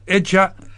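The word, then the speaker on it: Agya: It's not very good. Eja is actually... Eja is the word for father, so it's a title of respect, and this is the professional name of a musician called Daniel Amponsa.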